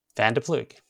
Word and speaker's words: van der Ploeg: And our guest is Neil van der Ploeg. – Thank you. I'm not good with pronunciation at the best of times, so I thought it's better for you to help me with that surname.